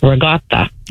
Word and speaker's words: ricotta: Yeah, people always pronounce, I can't even say it, ricotta. It's regatta. – Oh, regatta. – We're not saying it correctly. – Of course not. – But that's like the real Italian way to say it.